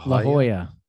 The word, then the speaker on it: La Jolla: I say that wrong, don't I? Is it La Jolla? – La Jolla. – Yeah. La Jolla.